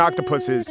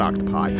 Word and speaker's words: octopuses: And octopuses is the right way to say it. It's not octopi.